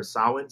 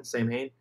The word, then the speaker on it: Samhain: I'm like fully committed now to finding out what the fuck Sam is, because Sam is obviously short for Samhain. Samhain. – Yeah. – For those of you who say it incorrectly.